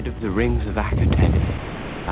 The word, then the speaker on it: Akhaten: I should have said that the, the episode we are talking about, because uh, we're having some argument about the pronunciation. Apparently, it's pronounced differently twice during the program, but Akhaten was the way that the doctor said it there.